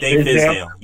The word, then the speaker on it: Fizdale: Fizdale hiring, that's how you say his name, right? – Dave Fizdale.